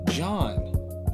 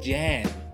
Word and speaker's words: Jan: John. That's J-A-N, Jan, okay? – But it's okay. Pronounced John. – But it's pronounced John, but there's people out there that say Jan, and it's hilarious.